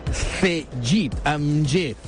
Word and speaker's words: Cegid: És Cellit. Es diu Cellit. Bueno, Cellit, ho diré en castellà, que és Cellit. Encara que s'escriu amb G, eh? Cellit. Però la pronunciació és G. Cegit, amb G.